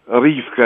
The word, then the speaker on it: Ривка: Ривка, это правильное произношение.